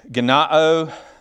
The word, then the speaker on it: ganao: All right, so let's get into ganao. It's a long O.